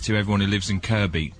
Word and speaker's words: Kirkby: It's Kirby Lonsdale, not Kirkby Lonsdale. – Yeah. Why did you say Kirkby? – Because it's spelt Kirkby, right? – As in, as is the place in Merseyside spelt Kirkby, but pronounced Kirby.